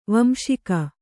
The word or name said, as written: ವಂಸಿಗ